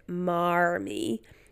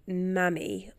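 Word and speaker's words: Marmee: I read that Louisa M. Alcott's accent was non-rhotic, so they don't pronounce R's. So that actually it's not like Marmy, but basically Mammy, or with an American accent, like Mammy.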